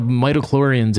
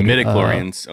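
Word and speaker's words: midi-chlorians: It's based on the amount of mitochlorians in your blood. – Midichlorians.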